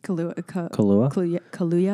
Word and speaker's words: Kaluuya: I think Judas and the Black Messiah, David Koyelo. Oh, I don't want to butcher his last name. – Kaluuya, I think.